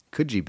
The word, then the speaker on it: Coogee: That's Coogee Beach. – Oh, yeah? – Oh, yeah. – Okay. – Coogee.